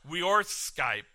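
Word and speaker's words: weorþscipe: It comes from the Anglo-Saxon word that is pronounced weorskype, or worth-ship.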